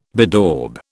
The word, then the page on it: bedaub: British English